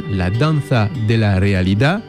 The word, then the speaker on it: La Danza de la Realidad: Et son dernier film a été présenté à Cannes, La Danza de la Realidad.